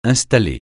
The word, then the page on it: installer: francais